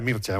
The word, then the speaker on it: Mircea: Sí, se pronuncia Mircea, uno que es muy friki de todos los rumanos, ya lo sabéis.